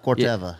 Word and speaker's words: Corteva: Corteva.